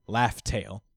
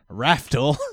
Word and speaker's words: Laugh Tale: Yeah, because they pronounce the L with the R. – Yeah, because everyone in One Piece is Japanese and say Raftel instead.